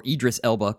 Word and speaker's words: Idris Elba: I hope you said Idris Elba or Idris Elba correctly. – Oh, Idris Elba. Okay, thank you.